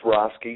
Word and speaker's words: Roske: Oh, it's Roske.